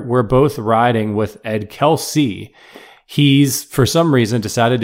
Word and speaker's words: Kelce: But we would honestly go by either one, but I prefer Kelce